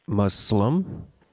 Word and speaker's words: Muslim: So, because it's one S, you know, we as Americans tend to say Muslim because one S is like is pronounced like a Z. But in fact, if you think of it as two S's, like how would you pronounce it if it was spelled M-U-S-S-L-I-M? How would you pronounce that? – Muslim. – Right, there you go. That's the way we say it.